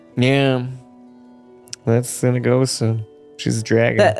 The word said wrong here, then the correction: Cyanigosa: I think it's like Cyanagosa. Not Sinagosa. Sinagosa is a different one. It's like Cyanagosa or something.